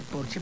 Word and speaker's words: Porsche: O decir que tengo un Porsche en lugar de decir tengo un Porsche, porque en alemán Porsche se dice Porsche porque todas las S al final se pronuncian como Marlene Dietrich, por ejemplo, que todo el mundo dice Marlene, pero es Marlene.